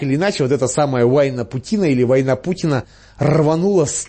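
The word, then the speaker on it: Уайнапутина: с названием Уайна-Путина. Правда, некоторые произносят это название более любопытным образом – «Война Путина».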